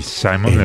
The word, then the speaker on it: Simon Le Bon: Y en un día como hoy, nació el señor Simón Le Bon. – Sí, Simon Le Bon.